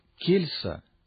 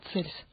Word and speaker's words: Кельс: Это полемическая работа против Кельса. – В латинском прочтении Цельс. – Да, если мы произносим по-гречески, то Кельс, а по-латыни против Цельса. Эта работа несколько необычная.